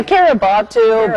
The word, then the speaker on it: Kiribati: Researchers think she landed on a part of the Phoenix Islands chain in the South Nation, Pacific Nation of Kiribati. Did I say that right? – Oh, Kiribati, but I've heard, but I like Butu.